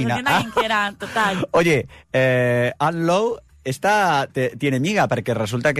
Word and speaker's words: Lowe: No, a ver, esta Ann Lowe, ¿Lowe lo pronuncio bien? – Sí.